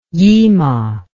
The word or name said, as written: yima